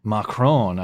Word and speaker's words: Macron: Macron.